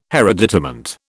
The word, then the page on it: hereditament: British English